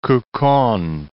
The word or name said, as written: Quqon